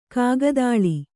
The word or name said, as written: ಕಾಗದಾಳಿ